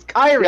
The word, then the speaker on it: Kairi: Kari. Kari. Kari Sane. They kept saying Kari. I'm like, what the fuck is a Kari? It's Kairi, yeah.